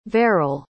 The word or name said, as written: verrall